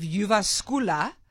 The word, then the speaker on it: Jyväskylä: But the university, and I actually looked up how to pronounce it, the University of Jyväskylä and the University of Eastern Finland followed these kids over a period of 8 years, 260 Finnish children over 8 years.